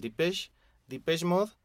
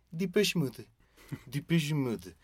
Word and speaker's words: Depeche Mode: Sí, y la pronunciación también puede variar, porque varios le dicen el Depeche Mode, y bueno, si se van al origen del nombre que ahorita vamos a aclarar, pues sí, es en francés y esa sería la pronunciación más atinada. Depeche Mode.